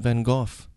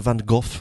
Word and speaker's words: Van Gogh: one of the British soldiers over there was the guy who played Vincent Van Gogh in, which is the correct pronunciation, by the way. – Uh-huh. – The Dutch pronunciation, which is where his family's from.